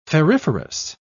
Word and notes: ferriferous: British English

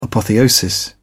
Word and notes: apotheosis: British English

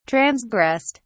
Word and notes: transgressed: British English

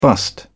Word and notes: bust-up: British English

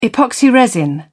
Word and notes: epoxy resin: British English

